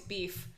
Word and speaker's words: biff: Fief? – Beef. – Fief. – Beef. – Beef? – It's spelled B-I-F-F.